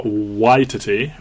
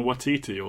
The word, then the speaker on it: Waititi: Directed by Taika Waititi, which is a shocking revelation to me on how you pronounce his name, based on him doing an intro for the film. I've been saying Waititi all these years. It's Waititi, but if I slip and say Waititi, sorry.